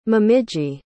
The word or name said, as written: momiji